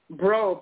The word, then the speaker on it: Breaux: G-R-O-W? – Mm-mm. Sean is spelled S-H. – No. Her last name. – Bro, B-R-E-A-U-X.